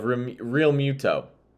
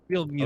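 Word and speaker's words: Realmuto: Okay, I thought you were going to say like they're not going to be JT Romulo. Is that how you say it? The Phillies catcher. – God, I don't. Real Muto. – Real Muto.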